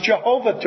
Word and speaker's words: Jehovah: Or as the Germans have it, Yehovah. Yehovah is what you have in Hebrew. Yehovah is what you have in German. And we bring it over into English and we pronounce our J's not like a Y, but with a hard sound. And so it becomes Jehovah to us.